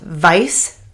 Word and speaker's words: Weisz: His birth name was, sorry, I might mispronounce this because it's Hungarian, but it's Eric what would be pronounced Weiss, which is like W-E-I-S-Z.